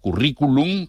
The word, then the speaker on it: currículum: Y se puede escribir, se escribe en letra redonda, sin cursiva, currículum, como suena, y con tilde en la I, como palabra esdrújula.